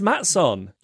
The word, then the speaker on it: Matson: Swedish. That's the Swedish pronunciation. It's not to rhyme with Datsun, like the car. It's Matson.